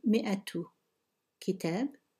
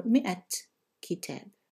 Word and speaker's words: مئة: So you say mi'atu kitab or mi'atun or mi'at kitab.